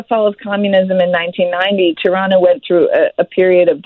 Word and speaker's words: Tirana: And the story that kicks off your book is about the capital city of Albania, which is a place called Tirana. Am I pronouncing that correctly? – Yes, Tirana, yes.